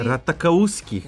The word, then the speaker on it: Ratajkowski: Ratakauski, kako se izgovara zapravo njeno ime.